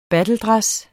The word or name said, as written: battledress